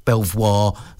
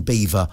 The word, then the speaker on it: Belvoir: with its name mispronounced by many as Belvoir rather than Beaver.